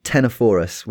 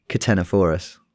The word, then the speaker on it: Ctenophorus: And all of the papers which we're doing today concern a bunch of Australian dragons, a genus of agamid lizards called Ctenophorus, which has a silent C in it. I didn't realize it had a silent C in it. I'm glad I Googled it, because I'd probably have just started saying Ctenophorus.